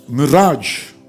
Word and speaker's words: mirage: How do you pronounce it? M-I-R-A-G-E. Mirage. Mirage. But tonight, the Holy Spirit is sending a word and He's setting people free from the spirit of stagnation.